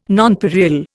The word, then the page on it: nonpareil: Indian